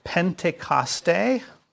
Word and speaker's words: Pentecost: The first one is Pentecoste, which is how you pronounce it in the Greek, but it is Pentecost, of course.